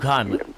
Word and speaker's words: Heimlich: You may have seen the one that he wrote yesterday about this is it Heimlich? Is that the way you pronounce it? – Yeah, Luke Heimlich.